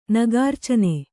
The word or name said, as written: ನಗಾರ್ಚನೆ